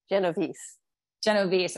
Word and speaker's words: Genovese: Genovese.